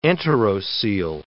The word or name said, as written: enterocoele